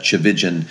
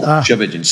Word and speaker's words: Tchividjian: I heard my mom say this in all my growing up years, that Tchividjian rhymes with religion. Sometimes that helps people. Tchividjian.